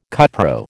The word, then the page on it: cupro: American English